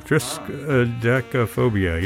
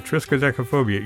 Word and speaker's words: triskaidekaphobia: Triskaidekaphobia, yeah, that's how you say it. Triskaidekaphobia.